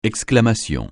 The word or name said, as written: exclamation